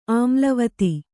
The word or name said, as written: ಆಮ್ಲವತಿ